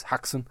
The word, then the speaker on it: Haxen: So Haxe or Haxen, depending, there are lots of different variations on the pronunciation of this. But here in Franken, it's Haxen.